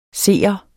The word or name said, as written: seer